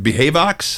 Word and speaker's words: Behavox: So Neil is the chief marketing officer at, am I saying it right, Behavox? – That's right, Behavox.